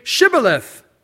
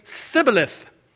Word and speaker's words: Shibboleth: And if he said no, if he was lying, then they would say to him, "Then say Shibboleth." And they would say Sibboleth. For he, the people of Ephraim, could not pronounce it right.